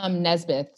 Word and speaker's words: Nesbitt: It's Nesbitt. So like the I and the E are reversed. So it sounds like N-E-S.